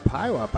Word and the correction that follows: Puyallup: No, it's called, not Pileup, it's called Puyallup. – Puyallup, thank you.